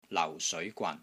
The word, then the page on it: LAU4 SEOI2 GWAN3: Cantonese